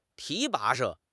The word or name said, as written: 提拔